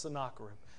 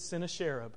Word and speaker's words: Sennacherib: My good friend Luke Manning cannot stand it when I talk about this guy because he refuses to accept the truth that it's pronounced Sennacherib. He believes that it's Sennacherib. It's not.